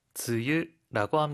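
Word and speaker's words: 즈유 (梅雨): 일본어로는 뭐라고 읽죠? – 즈유라고 합니다.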